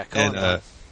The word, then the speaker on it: Cullen Bunn: Yeah, Cullen Bunn.